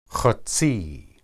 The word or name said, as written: hetsi